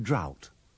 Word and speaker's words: drought: Don't pronounce the GH in drought.